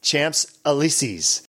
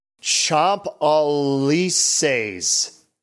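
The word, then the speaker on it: Champs-Élysées: What we called it, I think, Champs Elysees, because it's spelled C-H-A-M-P-S and then E-L-Y-S-E-E-S, Champs Elysees, which I guess is wrong. So let's see. According to this, it's Champ Elysees.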